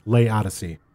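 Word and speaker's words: Laodicea: And this is how it's spelled: L A O D I C E, which to me says Lodice, but that's not how it's pronounced. It's pronounced Lay Odyssey.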